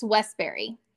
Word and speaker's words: Westberry: It's Westberry. – West and then Berry, right? – Yeah.